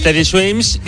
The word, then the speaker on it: Teddy Swims: Se llama, a ver si lo digo bien, Teddy Swims. – Es Teddy Swims, y esta es una canción que hace con Tiago.